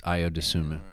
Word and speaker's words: Ayo Dosunmu: So one guy who played really well for the Bulls last year, he was on my fantasy team at one point, Ayo Dosunmu, I think that's how you pronounce it. – Yes, Ayo Dosunmu.